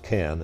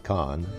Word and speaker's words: Cannes: We did get to walk the red carpet at Cannes, which is funny, and it is pronounced Cannes and not Con.